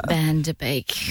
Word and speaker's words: Van Der Beek: James Van Der Beek.